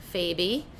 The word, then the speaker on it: Faby: My last name is spelled F-A-B-Y. You can follow me on Instagram at Lauren Beth Faby.